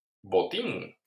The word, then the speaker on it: Botín: Y en español, bueno, le pusieron simplemente un acento en la I y se llama Botín.